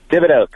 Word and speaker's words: Thibodeau: Am I saying that correctly? – Thibodeau, correct.